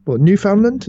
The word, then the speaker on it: Newfoundland: I think it was actually in, Newfoundland? Is that how you say it? – Yeah. – Newfoundland? – Yeah. They would probably say it like that.